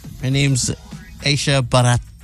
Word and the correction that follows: Basharat: Basharat. – Okay. Aisha Basharat.